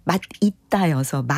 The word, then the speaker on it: 맛있다: 그게 사실 두개다 발음이 맞는데 이제 맛있다가 원칙이지만 맛있다도 허용이 되는 네, 그런 상황이 예, 사실은 네, 표준 발음법에 있기는 합니다.